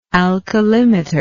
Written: alkalimetry

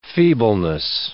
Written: feebleness

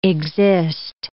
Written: exist

